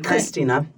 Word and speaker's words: Christina: Christina. – Mm-hmm. But you spell it with a CH. – Mm-hmm. – Okay, so in German, you can do CH as a K sound.